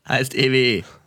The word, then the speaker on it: EWE: Niemand kommt aus Oldenburg, aber Yannick Brunke kommt hier aus der Area und war so, Digga, heißt EWE.